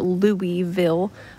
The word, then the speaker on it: Louisville: They, anything that has a vill at the end, they say vull, like Louisville, Kentucky. It looks like Louisville or Louisville, but Louisville, I don't know who came up with that.